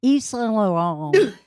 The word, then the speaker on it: Yves Saint Laurent: I said it French. Yves Saint Laurent.